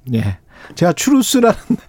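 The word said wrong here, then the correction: truth: 트루스죠, 트루스. 트루스, 추루스. 예.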